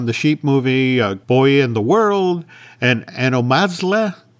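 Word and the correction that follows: Anomalisa: Am I saying that name wrong? I'm saying that name wrong. Anna Melissa. – There we go.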